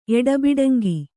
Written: ಎಡಬಿಡಂಗಿ

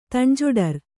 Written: ತಣ್ಜೊಡರ್

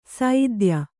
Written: ಸೈದ್ಯ

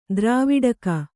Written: ದಾರವಟ್ಟಗ

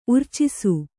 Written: ಉರ್ಚಿಸು

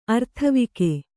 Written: ಅರ್ಥವಿಕೆ

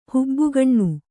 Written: ಹಬೆಗಣ್ಣು

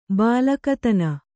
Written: ಬಲುಗೈತನ

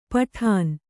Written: ಪೀಡನ

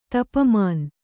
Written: ತಪಮಾನ್

